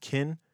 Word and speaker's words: Ken: To his friends, he was Ken, pronounced Kin, and everyone else, he was McElroy.